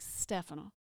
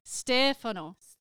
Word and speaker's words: Stefano: And I have to correct my friends because some of my friends still call him Stefano. I'm like, Stefano. Yes. The accent is on Stefano. – Stefano. Yes.